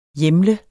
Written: hjemle

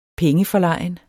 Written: pengeforlegen

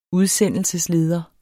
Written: udsendelsesleder

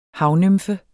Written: havnymfe

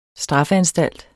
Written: straffeanstalt

